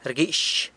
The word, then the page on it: rguix: sanlucasquiavini